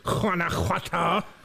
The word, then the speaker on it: Guanajuato: Guanajuato.